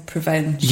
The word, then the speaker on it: Prevenge: There's two schools of pronunciation, but I say Prevenge.